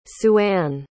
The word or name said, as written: sueanne